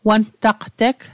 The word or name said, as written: wantaqteg